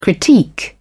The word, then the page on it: critique: British English